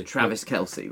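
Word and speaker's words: Travis Kelce: How do you pronounce this man's surname? Is it Kelsey? – Travis Kelsey. – Travis Kelsey, right.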